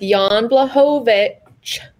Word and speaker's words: Jan Blachowicz: I'm going with Jan Blachowicz.